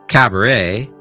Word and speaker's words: cabaret: Entonces, recuerde en francés, muchas palabras terminan con una T muda, lo que significa que la T final no se pronuncia. Por ejemplo, en palabras como ballet, cabaret o buffet, la T al final es completamente silenciosa.